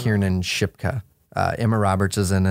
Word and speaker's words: Kiernan: Kernan or Kiernan? – Kiernan, yeah.